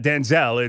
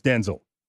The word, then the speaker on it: Denzel: All right, so it's not Denzel, it's Denzel. But I'll go with Denzel as the mass majority, and I don't think he minds. Little emphasis there on the first syllable.